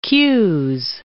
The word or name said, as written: qs